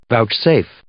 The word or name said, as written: vouchsafe